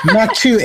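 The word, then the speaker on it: maque choux: M-A-Q-U-E. You know, you're from, you did. – I'm trying to find it. – Mac chow, M-A-Q-U-E, and then C-H-O-U-X. You worked at a New Orleans-style restaurant before, right? – Yeah. – I believe it's mac chow.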